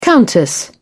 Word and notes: countess: British English

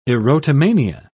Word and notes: erotomania: British English

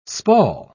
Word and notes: spall: British English

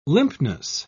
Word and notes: limpness: British English

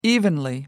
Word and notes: evenly: American English

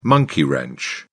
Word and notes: monkey wrench: British English

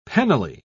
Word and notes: penally: British English

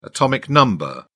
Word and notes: atomic number: British English

